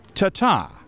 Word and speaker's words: Taghta: The winner, and I need to be really careful here, is spelled T-A-G-H-T-A and pronounced Tata.